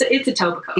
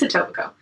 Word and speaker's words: Etobicoke: So, and for anyone who's wondering, it's Etobicoke. – Yeah. – Which we know is weird considering the way it's spelled, but it's Etobicoke.